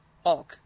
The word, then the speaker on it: Hulk: Hawk? – Hulk. – I did not think... Oh, Hulk. – Hulk. – I think I said Hawk. – Hawk. – Goddamn Americans. – Yeah. Goddamn Americans pronouncing your L's like W's.